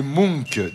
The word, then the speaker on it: Munch: Ça s'écrit M-U-N-C-H et je crois qu'on prononce Munch.